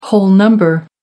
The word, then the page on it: whole number: American English